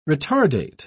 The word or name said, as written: retardate